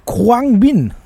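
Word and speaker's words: Khruangbin: Oh ja, die heißt Kruang Bin.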